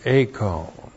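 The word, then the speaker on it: Acone (εἰκών): Now that brought us to the word acone. E-I-K long O-N. Acone.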